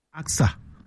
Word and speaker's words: AKSA: I don't know whether how you pronounce that, whether you call it AKAS or or AXA. I don't know, but he said AXA. – Oh, so you call it AXA. Okay. – Yeah. – Okay, let's say AXA, the AXA project.